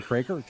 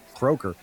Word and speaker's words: Craker: I was saying Croker. He goes, everybody pronounces it Croker. But I preferred Craker because Croker sounds stupid.